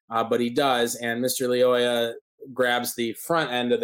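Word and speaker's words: Lyoya: I heard a family, one of his aunts said Leoya, so I'm going Leoya. Leoya. Okay. – But I have heard it. – So Leoya, we'll go with that.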